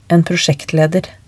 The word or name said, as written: en prosjektleder